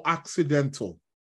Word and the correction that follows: occidental: Occidental, let me pronounce it correctly.